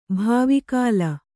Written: ಭಾವಿಕಾಲ

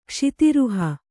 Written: ಕ್ಷಿತಿರುಹ

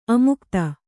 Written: ಅಮುಕ್ತ

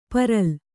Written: ಪರಲ್

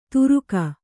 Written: ತುರುಕ

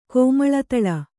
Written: ಕಿಮುಳ್ದುಳಿ

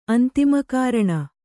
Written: ಅಂತಿಮಕಾರಣ

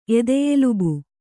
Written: ಎದೆಯೆಲುಬು

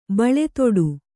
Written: ಬಳೆದೊಡು

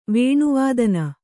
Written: ವೇಣುವಾದನ

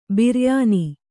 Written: ಬಿರಿಯಾನಿ

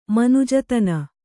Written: ಮನುಜತನ

